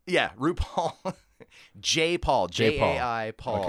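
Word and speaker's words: Jai Paul: That's the way it looks, but so the very first track on the album is some guy going like, it's J. Paul. Like, he keeps saying J. Paul. So that's, I'm taking it from the way it's pronounced on his own album.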